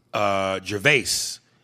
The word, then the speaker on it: Gervais: Gervais, pronounced like Ricky Gervais, but the S is silent.